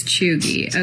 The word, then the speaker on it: cheugy: It's a term called chuggy. – How do you spell it? – Chuggy is C-H-E-U-G-Y.